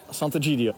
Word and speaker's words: Sant'Egidio: De gemeenschap van San Egidio is dat, hè? Hoe spreek je het uit? – Ja, Sant'Egidio. – Sant'Egidio.